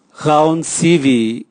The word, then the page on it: gaonsivi: Raga